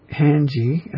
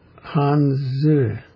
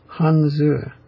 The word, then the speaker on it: Hanzi: And by the way, the Chinese written characters are not called Hanji. Again, I have to refine it further, it's pronounced Hanzi, Hanzi. It's H-A-N-Z-I.